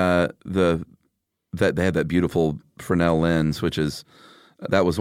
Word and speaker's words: Fresnel: It's spelled Fresnel, but yeah. it's pronounced Fresnel, and it's that, Coke bottle, you know, ribbed, right.